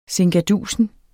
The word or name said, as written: sinkadusen